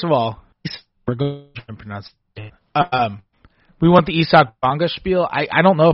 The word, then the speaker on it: Isak: And so people started calling him Isak because that's the German pronunciation.